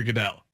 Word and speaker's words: Goodell: And yes, I mispronounced Goodell.